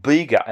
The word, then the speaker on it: Bega: It's called Bega, and it's spelled B-E-G-A, Bega.